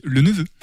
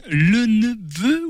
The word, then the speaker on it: Lenepveu: Alors sur qui me direz-vous Vous, l'avez compris, Jules Eugène le neveu ou le neveu C'est quoi la prononciation exacte Alors les Angevins ont tendance à dire le neveu, mais les descendants disent le neveu, donc on peut faire une espèce de compromis, mais en tout cas les deux prononciations sont possibles.